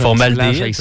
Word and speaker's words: formaldéhyde: C'est différentes choses pas dangereuses pour la santé, mais entre autres, oh ma prononciation, formylade. – Formylade. – Formylade. Ah, oh, là, je pense que j'ai formaldéhyde, ça se peut-tu? – Oui, bravo. Merci, okay.